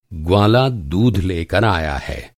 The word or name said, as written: ग्वाला